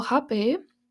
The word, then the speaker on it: Rapé: So, Hape which is also um, spelled as R A P E H. So R A P E with accent or R A P E H, and it's um, pronounced hape,